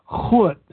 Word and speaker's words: goud: Well, anything that starts with a G in Afrikaans or Dutch, you have to sound like you're clearing your throat. Like the name of the second to last song, it's spelled G-O-U-D, and it's pronounced Hood.